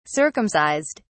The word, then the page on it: circumcised: British English